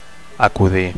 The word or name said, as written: acudir